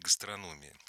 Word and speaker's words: гастрономия: Не просто поесть, а любитель гастрономии, любитель новых вкусов. – Да. – Кстати, нас сейчас с вами поправлять будут. Оказывается, правильно говорить гастрономия. Оказывается. Я всегда говорил гастрономии, потому что профессионалы всегда говорят гастрономии.